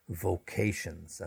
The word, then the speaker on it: vocation: Now, when we come to people who work in the medical profession and also teachers, we often refer to their professions as vocations. And a vocation, get the stress on the V-O-C-A-T-I-O-N, a vocation is a calling,